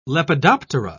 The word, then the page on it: lepidoptera: British English